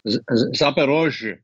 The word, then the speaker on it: Zaporozhye: It's also Kherson and, help me out with that pronunciation. – Zaporozhye. – Yeah, like what he said.